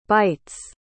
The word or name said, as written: bytes